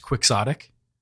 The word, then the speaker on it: quixotic: Is it technically pronounced quixotic? – Yeah. – That's fucked. In Merriam-Webster's. I don't like it.